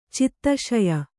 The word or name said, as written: ಚಿತ್ತಶಯ